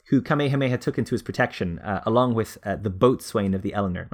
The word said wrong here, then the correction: boatswain: Bosun. It's pronounced bosun. – Really? – Yeah, bosun. – Yeah, inexplicably that word is pronounced bosun.